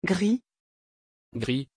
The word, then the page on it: gry: French